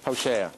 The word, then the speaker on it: Hosea: Hosheach? Is that how you say it? – Hosea.